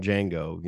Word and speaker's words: Django: And he goes, I'm Django. You know, how do you spell that? D J N G O D is silent.